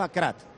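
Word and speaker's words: Aguerd: Mira, los marroquíes pronuncian Nayef Akrad. – ¿Akrad es el de Nayef Akrad, como te está sonando? – Se escribe los Aguer. Marroquíes. – Pero nosotros le llamamos Aguer.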